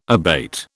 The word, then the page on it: abate: British English